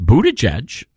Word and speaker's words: Buttigieg: Who's going to play Liz Cheney, Adam Kinzinger, Pete Buttigieg? It's Buttigieg. Oh, my apologies. I've been mispronouncing it for years.